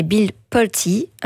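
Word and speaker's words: Pulte: C'est pas Bill Poult, mais Bill Poulty, un riche homme d'affaires, ami de Trump et désormais chef du renseignement américain.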